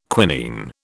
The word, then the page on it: quinine: British English